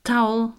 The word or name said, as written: towel